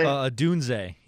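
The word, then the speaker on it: Odunze: Adunze? – Adunze, yeah.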